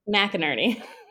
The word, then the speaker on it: McInerney: McInerney.